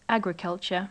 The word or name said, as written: agriculture